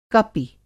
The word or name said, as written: कपि